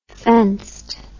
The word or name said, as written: fenced